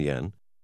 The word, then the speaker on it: yen: dollar is now worth about 120 to 140 yen. Although in writing it is symbolized as yen, its pronunciation is more like N. – There are four notes: 10,000 yen, 5,000 yen, 2,000 yen, and 1,000 yen that are of different sizes and colors.